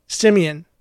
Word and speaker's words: Simeon: This comes to us from Simeon from Simon. – Nope. – Simon. – Nope. He says it right there at the end. His name is pronounced with an E. It sounds like monkey. Simeon.